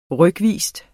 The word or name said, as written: rykvis